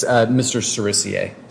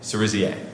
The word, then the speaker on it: Cerisier: It's Mr. Cerisier. – Cerisier.